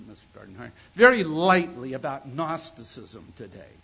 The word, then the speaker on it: Gnosticism: Any time you talk about a word that is pronounced Gnosticism that starts with a G, you know that there's something the matter with that, you know? You should never have words start with G and you pronounce them Gnosticism.